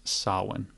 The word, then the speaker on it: Samhain: It looks like Samhain, but it's pronounced Samhain.